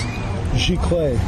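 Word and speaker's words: giclée: Maybe I'm an idiot, but is it pronounced Gicle or Gicle prints? – Gicle. – Gicle. – French.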